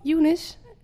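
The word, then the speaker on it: Eunice: Younes. – Ze heet toch Younes? – Ik had dacht iets met een E. Ja? Oh, dat spreek je zo uit.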